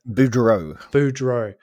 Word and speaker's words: Boudreaux: Parker Bordeaux or Bordeaux? How I keep saying it incorrectly. Parker Boudreaux.